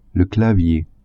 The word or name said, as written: clavier